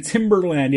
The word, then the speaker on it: Timberland: And Joe Santana said, remember to pronounce the R in Timberland. Yeah. Timbaland is the music producer. Timberland are the boots and Alaska.